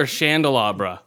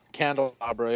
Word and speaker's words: candelabra: Is it Candelabra or Chandelabra? – Candle. – Is it? Everyone. – I think it's Candelabra, yeah.